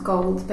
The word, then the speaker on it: Gould: I think it's pronounced Gold, but it has a U in it.